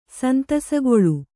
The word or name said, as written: ಸಂತಸಗೊಳ್ಳು